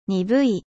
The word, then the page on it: terne: French